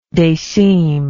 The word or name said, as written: decime